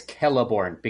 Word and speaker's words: Celeborn: And I think I might have tricked you, Jimmy, because I think I mispronounced it, because I think it's Celeborn, because it's spelled the same way as Celebrimbor from the video game.